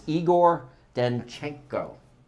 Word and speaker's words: Igor Danchenko: It is Igor Danchenko.